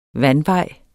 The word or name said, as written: vandvej